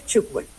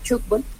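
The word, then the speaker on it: tchoukball: Cuando tengas la oportunidad, el balón cuando rebota contra una malla, contra un marco, hace ese sonido como ch. Entonces por eso se pronuncia y se denomina así, tugbell.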